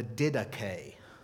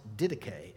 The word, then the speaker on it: Didache: Well, the earliest place that it seems to show up is in a document called the Didache. That word looks like didach, but it's pronounced didache.